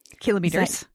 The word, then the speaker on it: kilometers: 260 kilometers. And it's pronounced kilometers. Thank you. I watch the news. – I pronounce it kilometers. – I know you do, but the newsreaders all say kilometers.